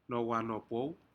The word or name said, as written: nohwanopou